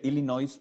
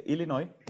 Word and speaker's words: Illinois: no se dice Illinois, por favor, se dice Illinois.